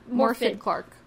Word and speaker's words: Morfydd Clark: Morfydd Clark.